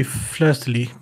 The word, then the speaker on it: fleur-de-lis: It should be fleur-de-lis with a silent S, isn't it? French is weird.